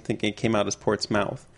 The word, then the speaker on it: Portsmouth: This first one was we pronounced Portsmouth, Portsmouth. – I might have done that by mistake. I know that Portsmouth, New Hampshire is Portsmouth, but...